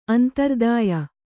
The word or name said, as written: ಅಂತರ್ದಾಯ